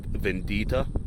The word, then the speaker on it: Bendita: When I walk into a bar like Vendita, it's spelled Bendita, but apparently the B's are pronounced V's here in Mexico.